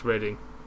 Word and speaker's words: Reading: Yeah, it's spelt Reading, but it's pronounced Reading.